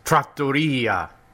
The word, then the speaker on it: trattoria: Hey, Coco, it's pronounced trattoria. You put the emphasis on the wrong syllable.